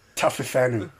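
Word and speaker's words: Taofifenua: Roman Taufafenu.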